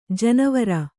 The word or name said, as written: ಜೇನವರೆ